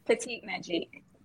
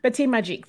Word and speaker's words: Petite Magique: Is that a pronunciation? Petite Mag? – Petite Magique. – Oh, sorry. See, in French. Petite Magique.